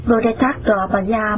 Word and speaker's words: Bodhisattva: Chữ Bồ Tát Maha Tát là tiếng Brahma ở Ấn Độ, nguyên âm đọc là Bodhisattva Maha Tattva,